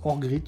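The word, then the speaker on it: Örgryte: Ça se prononce Orgrit, je pense.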